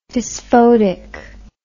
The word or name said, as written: dysphotic